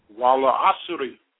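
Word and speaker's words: Wawl asri (والعصر): We just jump the ass sound and say wawl, wawl asr. But in reality, we have a kasra on that ra, but we don't pronounce it, but we do write it. Wawl asri.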